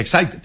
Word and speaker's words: Exide: Se escribe Exide, se pronuncia Exide, dentro la...